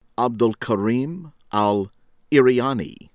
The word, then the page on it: Abdelkarim Al-Iryani: American English